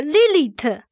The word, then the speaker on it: Lilith: 这个字是一个阴性名词，读音是 Lilith，Lilith。这这个怪物，它这个 Lilith，它的字的源头就是夜，所以它很自然的被理解成是夜间的恶魔。